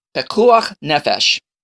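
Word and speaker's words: pikuach nefesh: Well, the answer is pekua nefesh. You're like, wait a second, what? Did you just have something caught in your throat? Pekuach nefesh. I even have trouble pronouncing it. Pekuach nefesh.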